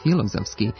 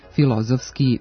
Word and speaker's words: filozofski: Ako medicinski, filološki i filozofski izgovarate na ovaj način, sa akcentom na prvom slogu, razgnevit ćete pre svega naše normativiste, budući da standardno jezička norma ovdje prihvata samo kratko uzlazni akcenat na drugom slogu. Dakle, medicinski, filološki, filozofski, biološki, pedagoški.